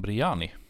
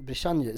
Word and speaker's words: Briani: Då vill jag säga att han heter Hassan Briani. – Jag hade sagt Brichanji. Så att det var tur att du uttalade det rätt. Vad du ville gärna prata lite om honom.